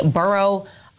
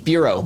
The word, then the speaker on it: Bureau: We also learned that last night a bomb squad and agents from the Borough of Alcohol and Tobacco. – Bureau. – What is the borough doing? What has Bureau? – Yeah.